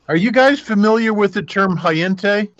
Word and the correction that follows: hentai: I think it's pronounced hentai. It's pronounced hentai, I think.